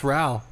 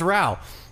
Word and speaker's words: Rao: I understand the people who are upset that my last name doesn't rhyme with bro. – I saw that conversation on the Facebook group where like people were trying to figure out how to say your name. – Yeah, yeah. Newsflash, it's Rao. – It's Rao. – Welcome. – Yeah, just rhymes with wow.